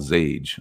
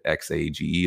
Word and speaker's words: Xage: Matter of fact, just today, there was another investment for $20 million around ZT for a company called Zage. I believe that's how you say it. X-A-G-E. Is it X-Age? I think it's Zage. Sounds cooler to say Zage.